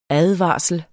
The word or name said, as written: ADVARSLEN